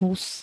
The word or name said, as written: hus